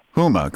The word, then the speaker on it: Huma: Yeah, Huma.